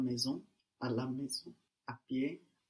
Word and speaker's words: pied: Pied. Pied. – Pied. Pied. The D on the end, we don't pronounce it, no? Pied.